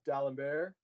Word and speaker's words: d'Alembert: but the D'Alembert principle, and I was entirely unclear on what that was. – Yeah, so I think it's pronounced D'Alembert. – I was close.